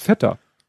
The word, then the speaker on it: Feta: Deshalb meint sie, das heißt Feta das ist ein griechisches Wort und es wird halt so ausgesprochen, wie es, äh, du sprichst ja auch englische Wörter englisch aus.